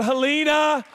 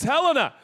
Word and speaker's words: Helena: Not Helena. These guest pastors come in, you know, they pronounce it Helena. It's Helena.